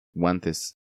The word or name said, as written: Guantes